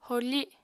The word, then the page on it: horfi: apatani